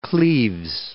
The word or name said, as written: Anne of Cleves